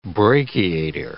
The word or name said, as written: brachiator